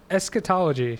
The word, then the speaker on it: eschatology: Well, according to this, one of the greatest contributions of Zoroastrianism to Western religions is the how do you say that? Eschology? Eschology? – Eschatology. – Eschatology.